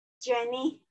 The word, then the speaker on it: Jenny: This Jenny. I'm from Toronto, Ontario, Canada. – Oh, welcome. Wonder, and I'm sorry I pronounced your name incorrectly. So it's Jenny, correct? – Yeah, JENNIE.